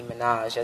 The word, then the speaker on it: Minaj: I mean, her name is Nicki Minaj, as in Ménage à Trois, even though it's spelled differently.